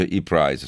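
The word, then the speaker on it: ePrice: Quindi vedo molti che mi stanno correggendo sul titolo di borsa, si pronuncia e-price, scusatemi, non lo sapevo, fa vendita online.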